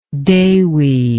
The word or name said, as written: dewi